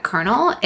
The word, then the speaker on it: colonel: I would never have known you pronounced that kernel if, like, I didn't actually know.